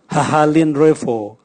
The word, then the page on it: hahalinrevo: Raga